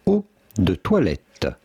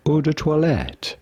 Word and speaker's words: eau de toilette: In French, we would say eau de toilette. Eau de toilette. Oh. But it'd be fine in English or American to say eau de toilette.